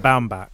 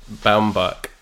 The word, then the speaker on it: Baumbach: And I wonder if that's because people laughed at Noah Bumbag. No, I can't even say his name properly because on my computer it also corrected it to Bumbag. But Noah Baumbach. – Baumbach, yeah. His previous films were called things like The Squid and The Whale, and I think people sniggered at that.